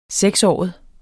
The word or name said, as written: seksåret